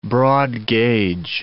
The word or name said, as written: broad-gauge